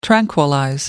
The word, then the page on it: tranquillize: American English